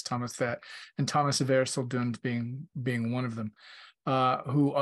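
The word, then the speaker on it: Erceldoune: Thomas of I think it's Erseldon. Is that how it's pronounced? – Erseldon. Yes. Right.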